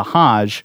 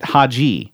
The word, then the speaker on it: Hajj: Mark noted that the pilgrimage taken by Muslims is called a Hajj, but pronounced it Haji. A Haji is a person that makes the journey.